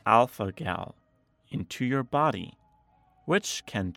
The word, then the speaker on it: alpha gal: The first one, alpha gal. That G makes a hard G sound. Alpha gal.